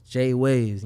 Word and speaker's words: Jwavves: Jwavves, you make sure you spell it with 2 V's. – 2 V's. – Not 1 V, you're going to find somebody random. Jwavves.